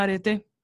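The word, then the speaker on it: arete (ἀρετή): bueno, la cosa es que estudiando eh, en mis clases de etimología grecolatina, hay una palabra que se llama arete, así se pronuncia, ¿no?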